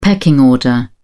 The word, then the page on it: pecking order: British English